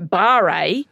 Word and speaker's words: barre: You know, it's bar, mate, bar, but, you know, what Barre is, right? – B-A-R-R-E, for those who are looking for the specific definition, and spin facilities, saunas, bathhouses, wellness centres.